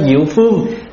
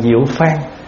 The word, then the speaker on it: diệu phan: Nhưng mà thay vì chúng ta nói diệu phương thì nó không cùng một cái vận với ở trên, cho nên đọc là diệu phan.